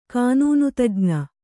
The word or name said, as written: ಕಾನೂನುತಜ್ಞೆ